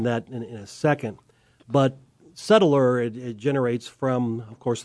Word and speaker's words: settlor: Is it settler or settler? S-E-T-T-L-O-R. – Correct. – Settler. In Oklahoma, we're going to make at least 3 syllables out of any word.